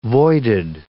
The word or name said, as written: voided